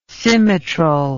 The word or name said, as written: Symmetrel